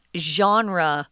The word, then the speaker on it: genre: Uh, genre. Why are you saying it like that? Because it's a French word. Uh, we pronounce it the way they do.